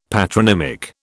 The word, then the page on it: patronymic: British English